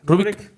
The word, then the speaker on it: Rubik: Rubik.